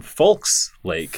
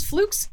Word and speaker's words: Fulks: So Folks Lake, spelled F-U-L-K-S, not F-O-L-K-S, like you might be assuming based on my... – Don't be a Folks. – Don't it's be a Folks. Folks, Folks Lake, Indiana.